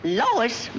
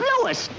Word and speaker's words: Louis: Lois? Me name is Lewis, not Lois. Gee whiz, everybody in Toypolis me name wrong. It's Lewis. L-O-U-I-S.